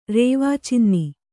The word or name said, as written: ರೇವಾಚಿನ್ನಿ